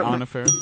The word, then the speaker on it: Anna Faris: I believe Anna Faris.